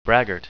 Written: braggart

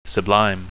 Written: sublime

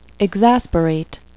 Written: exasperate

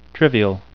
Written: trivial

